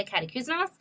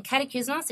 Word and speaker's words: Catacouzinos: So you just have to type in my full name, Claire Katakouzenos. – Do you want to spell that? – Yeah. So it's Claire, so C-L-A-I-R-E, and then Katakouzenos, it's 12 letters. So C-A-T-A-C-O-U-Z-I-N-O-S, and I'm on WordPress.